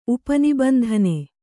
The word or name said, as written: ಉಪನಿಬಂಧನೆ